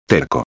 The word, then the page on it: terco: espanhol